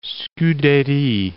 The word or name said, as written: Scudery